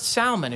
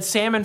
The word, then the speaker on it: Salmon: It's pronounced Salmon if you go to the Hebrew. I said salmon for years, and then I thought I better look at the Hebrew and see how they pronounce it. And so it's Salmon. There's an L in there, just so you know.